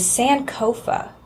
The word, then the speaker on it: Sankofa: Actually, the theme for this year is Sankofa.